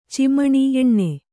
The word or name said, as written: ಚಿಮಣಿ ಎಣ್ಣೆ